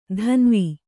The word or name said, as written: ಧನ್ವಿ